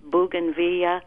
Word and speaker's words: bougainvillea: And my I had called before the freeze about, and I never pronounce it correctly, bougainvillea. – You nailed it. That's exactly how you say it.